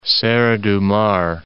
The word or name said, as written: Serra do Mar